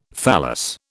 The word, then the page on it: thallus: British English